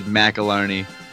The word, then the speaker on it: McAlarney: So if you were at a family reunion, you would get a pat on the back if you said McAlarney. But anything, you know, within 100 syllables of that is acceptable.